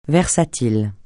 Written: versatile

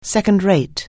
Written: second-rate

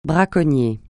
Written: braconnier

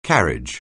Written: carriage

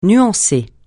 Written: nuancer

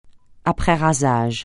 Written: après-rasage

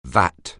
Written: vat

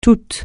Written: tout

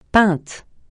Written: pinte

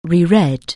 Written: reread

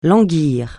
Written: languir

